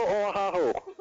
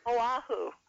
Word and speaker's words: Oahu: You tell them, though, Central Oahu. – Okay. – Or however you pronounce that. – Oahu. – Oahu.